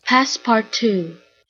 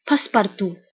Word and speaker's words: Passepartout: Passepartout, French passepartout.